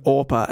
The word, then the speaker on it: Orpah: Okay, I've just got to say, fair enough if you don't know how to pronounce the name, but there's no way you're mispronouncing Orpah and being like, I reckon that's Oprah.